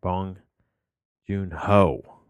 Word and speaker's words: Bong Joon-ho: I was saying his name wrong. Bong Joon-ho.